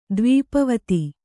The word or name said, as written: ದ್ವೀಪವತಿ